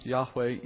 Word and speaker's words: Yahweh: Sometimes pronounced Yahweh in English, but that's not his name.